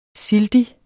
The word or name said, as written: sildig